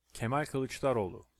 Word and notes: Kemal Kilicdaroglu: Turkish

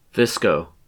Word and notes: VSCO: U.S. English; Midwestern accent

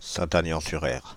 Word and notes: Saint-Agnan-sur-Erre: Paris

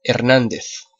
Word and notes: Hernández: European Spanish

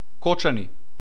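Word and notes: Кочани: [ˈkɔtʃani]; Macedonian

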